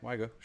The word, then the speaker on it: Wagyu: I had Wagyu beef. – Okay. – Am I saying it right? – Yeah, Wagyu. Sure.